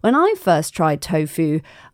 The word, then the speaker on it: Tofoo: It's spelled T O F O O, tofu,